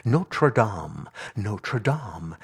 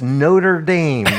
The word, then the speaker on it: Notre Dame: It's always so funny in this country when the news does something about that, the beautiful cathedral in France, they always say it correctly. Notre Dame. And when they're talking about football, it's Notre Dame.